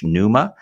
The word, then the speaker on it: Neuma: He is the global senior pastor of NUMA Church, NUMA, that's spelled N-E-U-M-A, after, of course, the Greek word NUMA, meaning spirit, Holy Spirit.